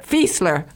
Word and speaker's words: Fiesler: Claire Fiesler, did I pronounce it right? – Fiesler. – Okay, well, you know, I'm sorry about that. Fiesler. – No, it's okay. A German last name, just too many vowels.